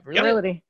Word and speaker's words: virility: virility how do you say that word Vir- yeah you got virility. it yeah virility.